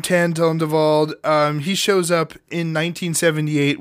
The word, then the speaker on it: Tondevold: So in comes this fella named Dan Tondevold. Tondevold? Yeah. Did I say it right? Yeah. Tondevold? – I think so. I think you got it.